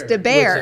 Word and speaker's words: DeBair: I say DeBar Design, and it's not. It's DeBair. – Everybody does. – For Barry. – Yes, it's the first syllable of my name.